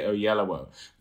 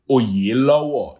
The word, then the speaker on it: Oyelowo: And he wouldn't even say, you know, I anglicize it and say Oyelowo, but he would say in the Yoruba, which is Oyelowo, is how you say Oyelowo.